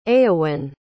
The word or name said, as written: eowyn